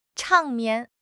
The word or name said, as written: 场面